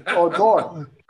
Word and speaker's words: Odor: How does Odor pronounce his name? Not the announcer. Odor, O-D-O-R. – Just how you said it. – Odor. – Just how you said it. – Yeah. – I thought maybe only the announcers did it that way. – No. The right pronunciation will be Odor.